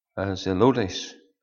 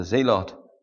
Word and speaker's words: Zelotes: It says, Matthew and Thomas, James the son of Alphaeus, and Simon called Zelotes, or some might pronounce it as the Zealot. Simon called Zealot or Zelotes.